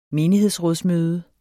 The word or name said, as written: menighedsråd